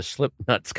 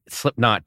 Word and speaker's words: Slipknot: So I queued it up to where the Slipknuts come on. And I go, hey, I recorded the Slipknuts last night. And he's like, Slipknot, dude.